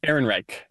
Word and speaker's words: Ehrenreich: Ehrenreich, is that how you say his name? – Ehrenreich. – Ehrenreich, thank you.